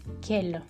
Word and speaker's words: quelles: Perceba que não tem diferença na pronúncia, é tudo kel, mas a escrita vai ser diferente.